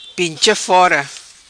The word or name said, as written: Pincha Fora